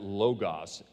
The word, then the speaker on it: Logos: In the original language, the word translated Word is the word Logos, or some people pronounce it Logos, L-O-G-O-S.